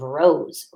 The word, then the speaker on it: rose: What do you hear at the beginning of rose? Rose. Yes, the letter R. And the last sound we can hear is rose. S. Kind of sounds like a Z, huh? But we spell it with an S.